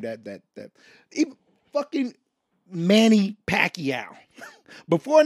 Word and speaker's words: Pacquiao: And then they come back and like, hey Max, I was told it's pronounced Pacquiao.